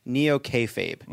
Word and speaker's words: neo-kayfabe: In your book, you describe the Republican Party's approach to reality as neo-kayfabe. – Mm. – What do you mean by that, and did I say it right? – You said it so right, yes.